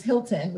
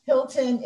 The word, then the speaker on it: Hylton: My last name is Hilton, which is H-Y-L-T-O-N, because why wouldn't it be? So I'm Hilton, H-Y-L-T-O-N, Daniel.